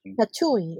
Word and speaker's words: Tatooine: Tatooine.